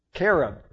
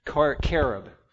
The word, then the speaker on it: Carob: I remember once my mom got a wise idea that instead of chocolate, she was going to get this karabi. Carob. That's it? Okay. That's how you pronounce it. Thank you. Carob. I got corrected.